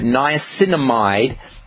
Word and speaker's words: niacinamide: One is called nicotinic acid, and the other form of niacin is called niacinamide. Niacinamide is no flush niacin, so if you are really concerned about the flushing, you can get the niacinamide form of niacin. They're both pretty much equivalent, but niacinamide, and that's spelled N-I-A-C-I-N-A-M-I-D-E, niacinamide, is so-called no-flush niacin.